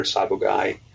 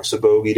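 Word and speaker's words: sabogae: And we'll mention those, like Boa Imperator Sabogai, or Sabogi, depending on how you want to pronounce it.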